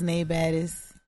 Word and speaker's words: Nai Baddest: My name is Nay Baddis. – Nay Baddis. – Spell it. – N-A-I-B-A-D-D-E-S-T. – Oh, hey. See, I would have never said N-A-I. I would have said N-A-Y. – N-A-I.